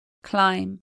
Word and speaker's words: climb: Silent B is an exceptional spelling. Bomb, atom bomb. Climb, climb up a cliff. Comb, chrome comb.